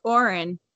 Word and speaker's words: aurin: Is that the how you pronounce it? – Yeah, it was very close. It's aurin.